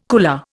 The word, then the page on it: cooler: Indian